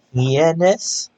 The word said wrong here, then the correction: heinous: I cannot say this episode name. Hyannis. – Spell it. – H-E-I-N-O-U-S.